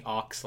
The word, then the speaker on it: Aux: It's spelled A-U-X. You can call me Ox like the cable. that makes music go in the car.